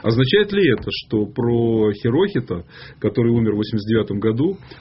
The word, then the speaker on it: Хирохито: Не положено ударение, нужно так ровно. Хирохито.